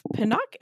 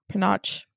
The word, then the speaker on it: panache: Um, so she dressed it up with a bit of panache and a streak of cruelty. Panache, panache, panache, panache. Maybe that sounds, which rhymes with ganache, which Ooh, really fits in to this candy house, which I know was not the point of that word, but.